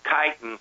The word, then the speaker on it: chitin: it's spelled C H I T I N, but it's, spelled, it's said chitin that material, the material that the mushroom is made of, is exactly the same material that a crab and lobster shell consists of.